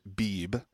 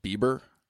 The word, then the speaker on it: Beebe: Apparently, one of the residents of, I'm going to pronounce it Beebe, Arkansas, B-E-E-B-E. – Can I call it Bieber? – Or it could be Beebe, or I think it's just Beebe.